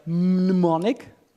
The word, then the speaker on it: mnemonic: This is a mnemonic. I don't know why they call it that, but it's called mnemonic. And the mnemonic. – Mnemonic. – Mnemonic. Mnemonic. Mnemonic. The M is silent.